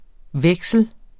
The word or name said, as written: veksle